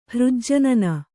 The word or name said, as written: ಹೃಜ್ಜನನ